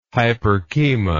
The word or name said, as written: hyporchema